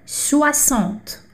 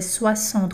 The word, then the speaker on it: soixante: Lembra da aula de hum, pronúncia, onde a gente tem o oi que é o a, né? Soixante. Suar, esse X aqui não é sex, tá? Então é soissant, como se fosse um som de SS, tá?